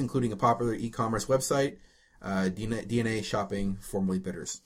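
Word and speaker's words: DeNA: Oh, yeah, so DNA... It is pronounced DNA. I thought so. Other people were calling it Dana.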